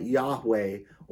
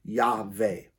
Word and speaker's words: Yahweh: But it's believed that they would pronounce that Yahweh, or sometimes the W sounds like a V, and it could be Yahweh.